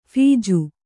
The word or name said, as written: ಫೀಜು